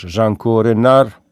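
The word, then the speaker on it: Django Reinhardt: 23 Γενάρη του 1910 γεννήθηκε ο Ζαν Τζάνκο Ράινχαρτ, ίσως Ζάνκο Ρενάρ, που είναι η σωστή προφορά στην βέλγο-γαλλική εκεί την γλώσσα.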